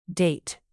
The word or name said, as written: date